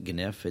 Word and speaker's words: GNIF: And directly to this, there's a forum that I linked to where a coder named I must pronounce it GNIF. It's G-N-I-F.